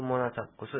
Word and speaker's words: モナサプ: モナサプ。モナサプじゃないんですね。モナサプという、ちょっと、プがほとんど聞こえないような発音です。これ、アイヌ語の、また、難しい音ですね。クモナサプス、ケアイカプワカン。